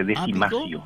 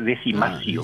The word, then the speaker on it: decimatio: Y se escribe decimatio, pues se lee decimacio. – Decimacio, obviamente. – Decimacio, por décimo, por diez.